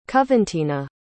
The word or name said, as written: coventina